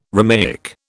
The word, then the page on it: Romaic: British English